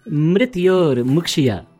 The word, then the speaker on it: Mrityor Mukshya: Mithror Mushki. – Mithror Mushki. No, it is Mrityor Mukshya. Mrityor. Yes. Mrityor.